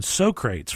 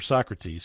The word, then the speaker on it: Socrates: And it gave us the pronunciation Socrates for Socrates.